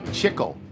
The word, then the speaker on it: Chicle: It's a song written by his son, Andy Edelman, performed by the band to which he belongs, Sweet Chicle, C-H-I-C-L-E, rhymes with tickle.